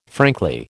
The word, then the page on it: frankly: American English